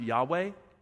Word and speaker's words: Yahweh: And it just means, it's pronounced Yahweh, and it just means I am.